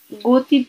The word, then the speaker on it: Guthi: que es Guti Dogs Park, que se escribe, el Guti es como G-U-T-H-I Dogs Park,